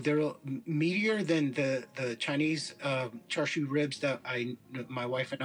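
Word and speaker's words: Char siu (叉燒): That's like SIU is like shu, but the pronunciation is it's Chinese.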